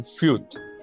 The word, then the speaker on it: Fürth: Fürth.